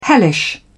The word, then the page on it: hellish: British English